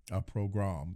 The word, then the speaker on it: pogrom: That's a pogrom. – a program. That's the correct pronunciation.